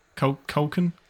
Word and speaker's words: Culkin: This entire trailer is cringy, and it's not quite Macaulay Culkin, is it? Culkin.